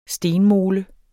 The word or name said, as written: stenmole